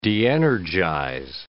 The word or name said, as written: de-energize